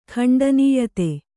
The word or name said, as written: ಖಂಡನೀಯತೆ